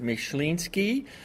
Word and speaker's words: Myslinski: If you're Polish, you pronounce it Myślinski, and it comes from the Polish word myśli, which means to think or thinker.